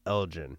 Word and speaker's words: Elgin: That hate should be more focused on someone like Ma- Michael Elgin. I'm sorry. Like, Yeah, I'm all, still all this you fucking on that. – that goes on with those guys. And that's like, hey Taco. It's actually pronounced Elgin.